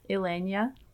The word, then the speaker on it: Elenya: Elenia. – Can you spell it? – E-L-E-N-Y-A. – N-Y-A, Elenia.